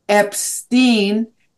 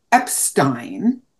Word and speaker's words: Epstein: And I said Epstein, and he immediately corrected me. It is Epstein.